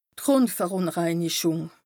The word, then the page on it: d' Grùndverùnreinichùng: Bas Rhin